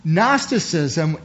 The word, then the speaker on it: Gnosticism: Gnosticism was a Greek philosophy, and if you break it down, the G is silent.